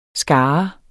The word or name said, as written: skare